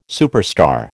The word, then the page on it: superstar: American English